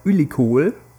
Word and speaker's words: ülikool: Zum Beispiel äh, Hochschule heißt Ülikol Mhm. Und du betonst das Kohl deswegen, weil das Kohl aus K-O-O-L okay. äh, geschrieben wird und deswegen ist die Betonung nicht.